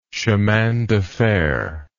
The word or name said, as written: chemindefer